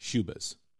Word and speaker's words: Schubas: Shubit? How do you spell that? – It's Schubas. Shubas. – Shubit. – Shubas, Schubas, Shubas.